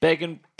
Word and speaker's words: Begin: Begin? – Rhymes with Reagan. – Oh, yeah, it makes more sense. Begin, Begin, Reagan, Palestine, terror on the airline.